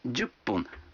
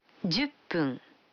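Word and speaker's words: джупун (十分): А 10 минут – джупун. Вы заметили, что когда фун добавляется к 10, дю, то произносится пун. – Послушайте и повторите каждую фразу. 5 минут. – Гофун. – 10 минут. – Дюппун.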